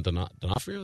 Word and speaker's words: D'Onofrio: Charlie Cox was on stage with Vincent D'Onofrio. Is that how you say his name? – Yep. – I think so.